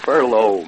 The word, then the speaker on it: furlough: and she said that Mikey's home on a furlong, so... – furlough, Ma. – So how did I say that? – You said furlong. – I said furlong. No, so that'd be furlough. No, that'd be like, he's coming to see us.